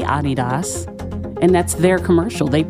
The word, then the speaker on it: Adidas: It's not pronounced Adidas. – Mm-hmm. – We're the only people in the world that pronounce it Adidas. – Okay. – Adidas. Is how to say it.